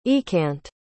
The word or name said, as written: eakant